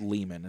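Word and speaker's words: Leiman: My last name is Lehman and it's spelled L-E-I-M-A-N. I remember very vividly as a kid thinking, Stein, that's just like my last name Lehman, L-E-I-E-I.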